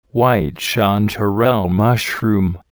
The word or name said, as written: white chanterelle mushroom